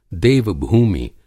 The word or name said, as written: देव-भूमि